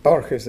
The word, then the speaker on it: Borges: I don't know if any of you have heard of a world-famous Argentinian writer, 20th century Argentinian writer, called Jorge Luis Borges, actually spelled B-O-R-G-E-S, and sometimes it's pronounced Borges in English, but it's really in Spanish is Borges.